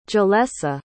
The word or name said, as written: jalessa